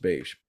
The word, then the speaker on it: Bache: It's confusing because it looks more like Bach, you know, the composer. Bach with an E, but pronounced Bache.